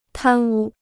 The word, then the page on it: 贪污: tān wū